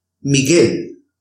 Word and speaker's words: Miguel: The combinations G-U-E and G-U-I, the U in between the G and the E is a silent U and is there to produce a softer sound for the letter G. Let's see some examples. Guerra, amague, Miguel, merengue, guitarra, guía. In the combinations of G-U-E and G-U-I,